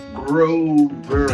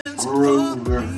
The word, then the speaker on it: Grover: I am Frankie Grover. And one of my biggest pet peeves, mispronouncing my last name, is Grover. Let's roll off your tongue. Grover.